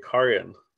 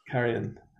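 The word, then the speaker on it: Cairhien: Well, actually, it was like a long A Carrion. – Carrion, okay.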